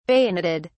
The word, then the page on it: bayoneted: British English